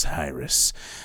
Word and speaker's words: heiress: hired to find an ex-lover of a glamorous heiress, why I pronounce the H, it's silent.